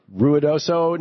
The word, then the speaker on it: Ruidoso: Ron in Ruidoso, New Mexico. Am I saying that right, Ron, watching Free Speech TV? – You said it exactly right, Tom.